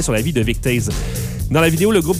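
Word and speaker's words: Toews: Donc, le ministre Vic Tease, j'ai entendu Tease, apparemment que ça se prononce différemment par différentes personnes, mais Vic Tease, le T-O-E-W-S, n'en a pas fini avec les remontrances des internautes.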